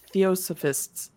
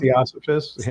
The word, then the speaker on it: theosophists: And it's just amazing that theory came about because there was also the theosophists. I think I said that wrong. – The theosophists. – Yeah. Theosophists. There we go.